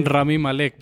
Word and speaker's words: Rami Malek: Digamos que los, el personaje principal, que se llama Elliot Anderson, que es protagonizado por Raimi, ¿cómo se dice? – Rami Malek, por Freddy Freddie Mercury.